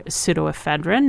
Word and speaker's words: pseudoephedrine: Get your rest, get your fluids, take your pseudoephedrine, not your phenylephrine, which has been finally acknowledged as not effective. – Say that one more time. – Okay. Pseudoephedrine. which is spelled with a P, and that's the one that you have to ask the pharmacist for because it is an ingredient in meth.